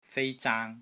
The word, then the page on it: FEI1 ZAANG1: Cantonese